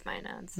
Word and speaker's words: maenads: I pronounce it maenads. But maenads? Yeah, it's maenads.